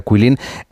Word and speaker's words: Guilin: internet se escribe G-U-I-L-I-N, como si fuera Guilin, pero se pronuncia Quilin.